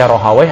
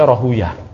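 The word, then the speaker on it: Rahawayh: Ada yang baca Rahway, ada yang baca Rahuyah. Tergantung, kalau ahli Nahu membacanya dengan Rahway. Kalau ahli hadis, membenci Wai-Wai. Rohuyah, nah, sebagaimana dikutip oleh ya, As-Suyuti di Tadribur Rawi. Kalau ahli hadis, demennya bacanya Rohuyah, Ibnu Rohuyah. Karena waih itu nama setan kata ahli hadis.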